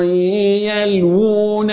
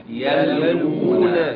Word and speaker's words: يلوون: أقول يلوو، انظر إلى الواو اهي يلوو، واو الهمدية دي يلوو، أي ما شاء الله ولا قوة إلا بالله. انظر، أيوه مصحف دار الإحسان الألوان اهي، اللون ده بيقول لك من فضلك اقرأ القرآن. هنا في مد طبيعي يلوو، يلوون يلوون ألسنتهم بالكتاب، مد طبيعي بالكتاب، بالكتاب تتنطق ازاي؟ – بالكتاب، بالكتاب.